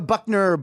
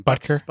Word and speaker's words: Butker: We needed a Buckner, Buck Harrison. How do I say his name? – Butker.